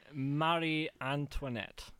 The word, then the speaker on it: Marie Antoinette: How do I say it? – Marie Antoinette.